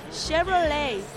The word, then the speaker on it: Chevrolet: Chevrolet, porque la CH no se pronuncia.